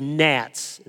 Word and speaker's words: gnats: So I was telling the plagues in Egypt, and I got to the G, and I did gnats. And so many moms have said, why? I'm trying to teach my kid how to read. You give him a silent G.